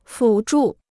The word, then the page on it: 辅助: fǔ zhù